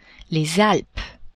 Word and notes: Alpes: French

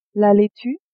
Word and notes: laitue: female

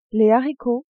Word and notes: haricots: female

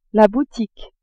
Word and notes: boutique: female

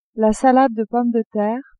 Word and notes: salade de pommes de terre: female

